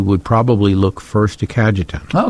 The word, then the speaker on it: Cajetan: Today, the church celebrates the feast of Saint, and you may have to help me out on pronunciation, Cajetan. – Cajetan.